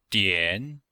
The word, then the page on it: dian: dian3